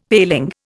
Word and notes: paling: Indian

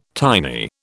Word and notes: tiny: British English

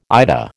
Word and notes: IDA: American English

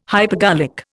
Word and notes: hypergolic: Indian

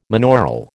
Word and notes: monaural: American English

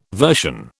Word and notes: version: British English

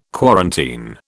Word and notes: quarantine: British English

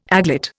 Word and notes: aglet: Indian

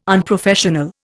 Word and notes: unprofessional: Indian

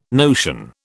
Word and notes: notion: British English

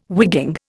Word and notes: wigging: Indian

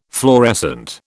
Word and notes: fluorescent: British English

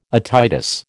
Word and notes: otitis: American English

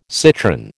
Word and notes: citron: American English

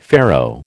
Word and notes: Pharaoh: American English

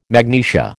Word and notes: magnesia: American English

